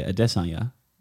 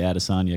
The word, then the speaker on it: Adesanya: I'm pretty sure it's in Auckland, Israel Adesanya, or I should say Adesanya is the correct way to say it. – Yep. – It's much more comfortable to say Adesanya because it flows better in sentences. – Yeah. You have to put the emphasis on the first E.